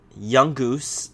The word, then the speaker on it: Yungoos: So, um, Young Goose is that even how you say it? Young. I don't even know how do you say its name. Its name is um, Yungoos.